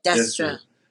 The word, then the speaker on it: Destra: Destra.